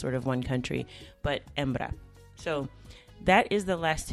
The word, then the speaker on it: hembra: And that's actually spelled with an H. And as you know, in Spanish, the H is not pronounced in any of the words. So it's H-E-M-B-R-A, hembra.